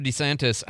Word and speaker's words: DeSantis: A little while back, Governor DeSantis, I can't believe I'm saying it that way now. I did it by accident. I know. Anyway, that is the right way to say it.